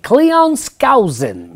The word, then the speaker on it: Cleon Skousen: How do you say their name? – Cleon Skousen.